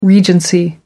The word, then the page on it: regency: American English